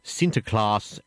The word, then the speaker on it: Sinterklaas: Yeah, well, and the other thing I was going to ask you about was Sinterklaas and Svartepieten. Am I saying that right? – Which is Netherlands Christmas. – That's right. Well, of course, Sinterklaas is Santa Claus.